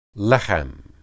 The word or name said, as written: lehem